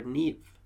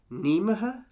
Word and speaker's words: Niamh: Like I had a friend of mine in, in, in a class, and her parents were Irish, so they called her Neve and Niamh is spelled N I A M H. So when you read it, my, our teacher read it out and they were like, Nimaha? – Nimaha?